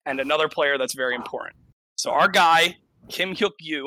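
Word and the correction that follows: Kim Hyuk-kyu (김혁규): which I'm never going to say his name again. – Kim Hyuk-kyu, cool.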